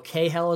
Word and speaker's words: Cahill: I know Cahill is right, which sounds kind of like kale, but whatever.